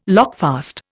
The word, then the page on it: lockfast: Indian